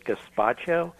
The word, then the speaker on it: gazpacho: Is it gazpacho? Mm-hmm. It's a kind of a tomato soup, but it's cold. I don't know if I'm pronouncing that right. You are.